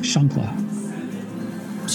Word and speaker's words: chancla: I think it's pronounced Shunkla. – Sure, we'll go with that. Thank you. – H-A-N-C-L-A.